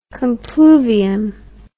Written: compluvium

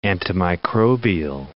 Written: antimicrobial

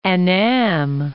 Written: Annam

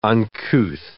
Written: uncouth

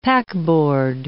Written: packboard

